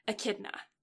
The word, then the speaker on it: Echidna: And where he's transported again to where he meets the Witch of Greed, Echidna. – I don't have to fix that pronunciation. You did well.